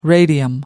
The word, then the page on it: radium: American English